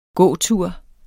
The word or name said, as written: gåtur